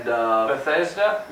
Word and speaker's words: Bethesda: Bethesda? – Yeah.